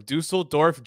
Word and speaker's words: Düsseldorf: So first of all, you pronounce Düsseldorf uh, much better than Rob after probably like 30 tries.